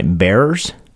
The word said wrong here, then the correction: barriers: We are bearers. – Barriers. Yes.